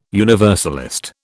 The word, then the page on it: universalist: British English